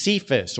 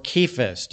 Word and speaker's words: Cephas: The name that was given to him by Christ is Cephas or Cephas, depending on how you pronounce it.